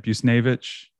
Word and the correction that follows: Buchnevich: Buchnevich and Barbashev.